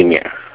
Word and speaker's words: Emir: My name is Emir. – Emir? Okay. – Yes. – How do you spell it? I'm sorry. – E-M-I-R.